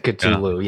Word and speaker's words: Cthulhu: I said Cthulhu, yeah.